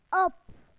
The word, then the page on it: on: British English